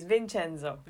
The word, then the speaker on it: Vincenzo: I mean, they pronounce it with the Korean accent, right? – Yeah, the bin, right? The, yeah. – With the b sound. – So for us, it would be Vincenzo, right? – Okay, it's Vincenzo.